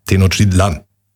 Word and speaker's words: Tenochtitlan: Der Apostroph ist auf, oder der, die Betonung liegt auf dem letzten A. Tenochtitlan.